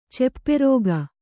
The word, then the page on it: ಚಪ್ಪರಿಕೆ: capparike